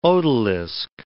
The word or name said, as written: odalisque